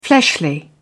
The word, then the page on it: fleshly: British English